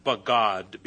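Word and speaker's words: bagad (בגד): Treacherously in the Old Testament, everywhere in the Old Testament from this root, bagad, B-A-W-G-A-D is the pronunciation. B-A-G-A-D is the spelling, the actual spelling of the Hebrew word.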